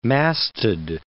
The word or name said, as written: masted